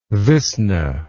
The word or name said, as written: visna